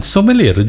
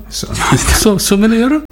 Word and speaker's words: sommelier: Da sommelier, giusto? Sommelier, perché sommelier. La ER francese si legge E e basta.